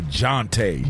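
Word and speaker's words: Jontay: How you say his name? – Jonte Porter. – You know, when you gambling, you know, you got to go Jonte. – Jonte.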